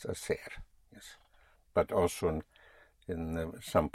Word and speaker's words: hacer: To do or to make is hacer. Spelled H-A-C-E-R. Again, the H is not pronounced, so you have hacer, to do.